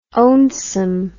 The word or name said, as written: ownsome